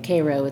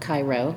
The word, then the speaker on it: Cairo: I was going to say, coming from Saugerties to Cairo, as the locals say, Cairo, it's not Cairo, right.